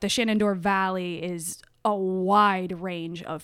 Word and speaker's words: Shenandoah: Shenandoah Valley. – Which is how you say it when you're from here. – Exactly. – With a hard O-R on the end, apparently.